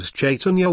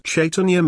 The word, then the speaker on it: Chaitanya: Do you know how long I thought the word Chaitanya was actually pronounced with a K sound in the front?